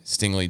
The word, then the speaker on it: Stingley: Not my second overall ranked player at all, but a corner out of LSU, Derek Stingley Jr. Saying that right? – Oh, yeah.